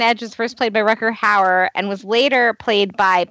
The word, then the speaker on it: Rutger Hauer: On Smallville, Morgan Edge was first played by Ruder. How do you say his name? Is it Rutger? Rutger Hauer? Okay. I'm going to restart. – On Smallville, Rutger Hauer.